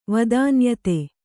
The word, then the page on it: ವದಾನ್ಯತೆ: Kannada